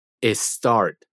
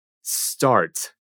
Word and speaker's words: start: Hay que intentar no caer en el error de pronunciar start como si tuviera una E al inicio o sea, start, sino que es start, iniciando con una S.